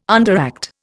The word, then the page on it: underact: Indian